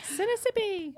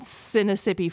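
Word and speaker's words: Sinnissippi: Sinisippi. – It is spelled exactly like Mississippi, just instead of M-I-S-S, it starts with S-I-N-N. So that's where I'm getting Sinisippi from.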